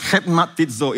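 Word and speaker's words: Hetmatizo (χρηματίζω): All right, it's pronounced Hetmatizo is a guttural sound.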